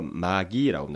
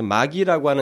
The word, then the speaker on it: magi: 이 동강 박사들은 어, 어, 당시로 보면은 그때 당시로 보게 되면 이 옛날 사회에서는 그 에, 동방 박사를 영어로 마귀라고 합니다. M A G I 마귀가 아니라 이게 발음상으로 마귀라고 하는데 그래서 마기라고 하는 이 앞에 어근이 붙는 뭐 매직, 뭐 매지션, 또뭐 매기스트레이트 뭐 이런 말 있잖아요.